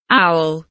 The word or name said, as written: owl